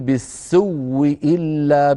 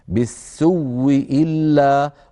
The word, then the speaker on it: بالسوء إلا: الوجه الثاني عن قالون وعن البزي أن نقول بالسوء إلا بواو مشددة، بواو مشددة، يعني نقول نبدل الهمزة واو وندغم الواو في الواو فيصير نطق بواو مشددة بالسوء إلا، وليس هذا مجال بحثنا.